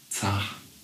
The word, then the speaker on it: zach: Und die ersten Male waren echt zach. – Habe ich es richtig ausgesprochen? – Sehr richtig.